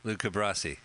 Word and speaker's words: Luca Brasi: Luca Brasi.